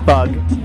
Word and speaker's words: 'Bug: My fly name is Bug. It's spelled apostrophe B-U-G.